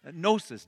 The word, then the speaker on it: gnosis: And the word knowledge at the end of verse 5 is the Greek word gnosis, G-N. Do I have it there? Yes, I do. Gnosis is how they pronounce it.